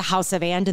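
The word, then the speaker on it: Haus of And: So we'll link up to House of And in the show notes, and it's spelt H-A-U-S of And.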